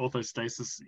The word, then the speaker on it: orthostasis: Anyone checked the tilt table or postural orthostatus as a marker? – Yep, postural orthostasis, yes.